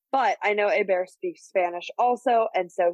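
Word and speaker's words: Héber: So effectively the H is silent in a very Spanishy kind of way. So Ebar, yeah.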